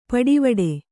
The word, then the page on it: ಪಡಿವಡೆ: paḍivaḍe